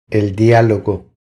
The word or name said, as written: El dialogo